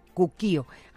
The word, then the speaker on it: Cuquío: Acatlán y Cuquío, con acento en la I. Cuquío, así se llama,